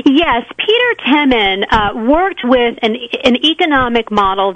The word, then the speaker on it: Temin: One of them was, Peter Temin, is that how it's pronounced, by the way? – Yes, Peter Temin.